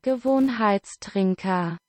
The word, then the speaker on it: Gewohnheitstrinker: It translates to habit drinkers in English, so a better pronunciation. – Gewohnheitstrinker. – That is it in German.